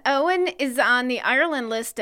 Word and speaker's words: Eoin: Okay, next one, E-O-I-N. – Ian. – Ian? – It's pronounced Owen. – Oh. – Owen. – As in the actor, like, Owen Wilson or Clive Owen. Exactly like that.